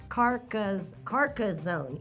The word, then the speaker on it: Carcassonne: Hold on, is it three syllables? Carcassonne? – Yeah. Carcassonne. Yeah, three.